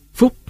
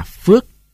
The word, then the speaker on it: Phúc: Phúc người Nam Bộ đọc là Phước.